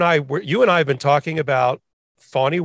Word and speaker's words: Fani: We've been talking about her so long, we finally figured out how to pronounce her name, because in the beginning it was like Fannie Willis in Fulton County.